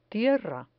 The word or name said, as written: tierra